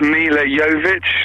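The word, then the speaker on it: Milla Jovovich: I think it's Mila Jovovich, the girl from The Fifth Element. – Completely correct. You pronounced her name very well.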